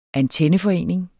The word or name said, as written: antenneforening